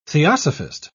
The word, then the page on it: theosophist: British English